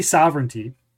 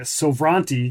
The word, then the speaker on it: Sovranti: So we say sovereignty, Yes. – but when I first saw it, I was calling it Sovranti because that's kind of how it looks.